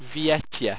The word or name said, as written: viyetya